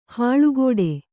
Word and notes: ಹಲ್ಕಡಿ: Kannada

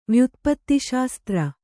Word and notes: ವ್ಯುತ್ಪತ್ತಿಶಾಸ್ತ್ರ: vyutpatti śastra

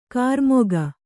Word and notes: ಕಾರ್ಮುಕ: kārmuka

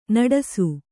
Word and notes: ನಡಸು: kannada

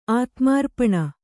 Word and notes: ಆತ್ಮಾರ್ಪಣ: ātmārpaṇa

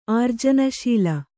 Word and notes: ಆರ್ಜನಶೀಲ: ārjanaśila

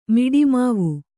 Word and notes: ಮಿಡಿಮಾವು: Kannada

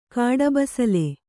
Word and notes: ಕಾಡಬಸಲೆ: Kannada

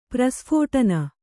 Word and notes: ಪ್ರಸ್ಫೋಟನ: prasphōṭana

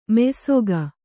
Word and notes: ಮೆಯ್ಸೊಗ: Kannada

